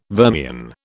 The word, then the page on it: vermian: British English